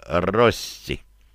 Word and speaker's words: Рости: Поэтому решил образовать королевство Рости. Ударение на 1-й слог.